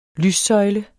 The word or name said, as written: lyssøjle